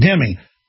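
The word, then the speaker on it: dhimmi: Dimmy, pronounced dimmy.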